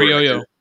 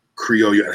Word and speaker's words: Criollo: C-R-I-O-L-L-O. When you put two L's together in Spanish, that's a yo sound.